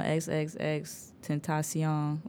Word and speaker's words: XXXTentacion: But obviously, he ruffled some feathers, a lot of them, and, you know, XXXTentacion.